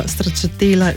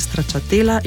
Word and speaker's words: stračatela: Stračatela že zapišemo oziroma se pojavlja zapis z navadnim slovenskim č, izgovorimo pa z oskim e, torej stračatela in ne kot bi izgovorili po italijansko, stračatela, torej s širokim ejem in nekako podaljšanim dvojnim lajem.